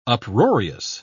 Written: uproarious

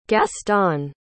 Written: gaston

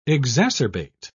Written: exacerbate

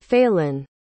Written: faylinn

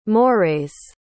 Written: morays

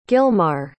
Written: gilmar

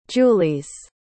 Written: julies

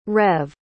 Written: reve

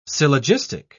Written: syllogistic

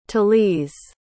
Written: talise

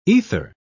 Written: ether

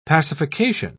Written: pacification

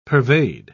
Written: pervade

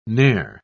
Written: ne'er

